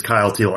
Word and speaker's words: Kyle Teal: Number one, I got to imagine rhymes with a smile meal. Correct? – It does. It does. Yeah. Number one right now is Kyle Teal.